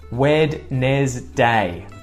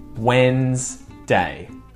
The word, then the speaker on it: Wednesday: No one would say Wednesday. I do say this in my head when I'm trying to spell it, but when I'm speaking, it's just Wednesday. Wednesday.